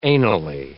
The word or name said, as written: anally